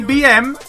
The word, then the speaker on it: BM: Sí, BM, sí, BM, lo dije bien.